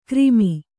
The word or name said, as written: ಕ್ರಿಮಿ